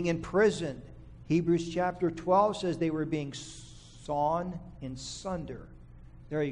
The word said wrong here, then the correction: sawn asunder: Sawn asunder.